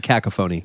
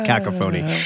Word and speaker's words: Cacophony: Of course, you mean Cacophony. – Cacophony. – I believe it's pronounced Cacophony.